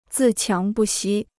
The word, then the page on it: 自强不息: zì qiáng bù xī